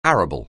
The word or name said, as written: arable